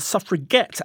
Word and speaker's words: suffragette: But then the tables were turned when they empowered the word by pronouncing it suffragette, as in get the vote.